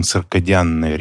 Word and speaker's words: циркадианные: Но на самом деле, вот среди профессионалов мы все-таки называем циркадианные ритмы, потому что корень слова – он относится к латинскому слову «диас» – «день», и в английском языке, соответственно, там тоже «диан» пишется. Поэтому мы произносим циркадианные ритмы.